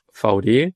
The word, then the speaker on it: Vaude: Aussprechen wird man uns VD. Hintergrund ist Firmengründer Albrecht von Dewitz. Der hatte die Initialen ein V und ein D.